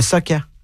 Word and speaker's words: socca: Comment ça s'écrit? – S-O-C-C-A. Le soccer. – Le soccer. – Oui. – Donc, pas le soccer, c'est le soccer.